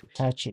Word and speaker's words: Hitachi: Is this Hitachi or Hitachi? How do you pronounce it? – Hitachi.